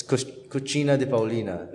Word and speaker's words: Cucina di Paulina: It's Cucina di Paulina. It's pronounced like that. But it's C-U-C-I-N-A-D-I. Paulina, P-A-U-L-I-N-A.